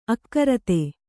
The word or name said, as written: ಆಕೃತಿ